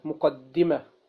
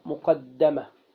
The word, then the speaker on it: مقدمة: كلمة مقدمة هكذا تنطق، مقدمة بكسر الدال وتشديدها، ويمكن أن تنطق مقدمة، مقدمة لأنها تقدم للكتاب، ومقدمة لأنها لأنها قدمها المؤلف على الكتاب.